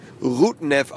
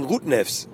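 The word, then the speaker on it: Rudņevs: Das erinnert mich so ein bisschen natürlich auch an unseren Spieler Rutnev, der definitiv Rutnev ausgesprochen wird, ohne das S, aber alle sagen Rutnevs.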